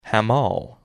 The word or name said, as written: Hamal